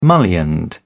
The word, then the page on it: mullioned: British English